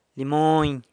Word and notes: limony: sanlucasquiavini

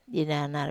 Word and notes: ananar: Wamesa

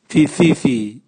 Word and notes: vivivii: Raga

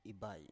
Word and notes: ibai: Biak

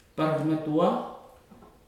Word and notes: paramatua: futuna aniwa

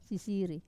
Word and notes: sasiri: wamesa

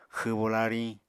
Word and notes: xwbo lariñ: Apatani

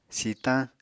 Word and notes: sitañ: Apatani

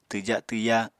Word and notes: twja-twya: apatani

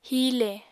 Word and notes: hiile: Apatani

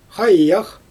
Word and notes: xajax: Xyzyl

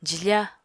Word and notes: jifá: apatani

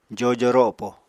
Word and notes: jojo ropo: Apatani

